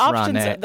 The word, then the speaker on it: Rahne: For the past 9 years, Rahne, parenthetical, pronounced R-O-N-N-I-E. – How on earth did he go to the Ivy League like that? Hey, what's your name? Ricky Rahne? – It's pronounced Rahne.